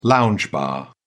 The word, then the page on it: lounge bar: British English